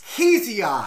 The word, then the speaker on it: Hesiod: Hesiod!